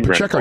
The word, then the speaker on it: Pacheco: I mean, Mahomes, Kelsey, just that whole team, Pacheco. Oh, I probably mispronounced that name. – But no, Pacheco, you got it.